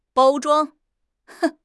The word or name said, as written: 包装